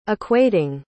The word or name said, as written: equating